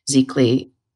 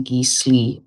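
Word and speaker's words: Giclée: It's just a print, but a beautiful Zeekly. Some people would pronounce it Geesley, but it's Zeekly, which is probably the best way an art print can be. – I pronounce it Geekley, but okay.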